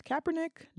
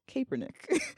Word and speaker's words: Kaepernick: Parentheses, his name is pronounced Kaepernick, not Kaepernick.